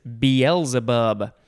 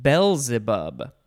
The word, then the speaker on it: Beelzebub: So the pronunciation and the spelling don't totally make sense to me, although it does look like one of the correct pronunciations is Beelzebub, Beelzebub, which makes more sense. It is capital B-E-E-L-Z-E-B-U-B. So you could say Beelzebub, Beelzebub, or Beelzebub.